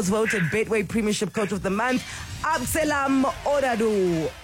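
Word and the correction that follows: Ouaddou: Oadu. Am I saying that right? Abdeslam Oadu. – That's it.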